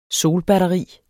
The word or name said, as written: solbatteri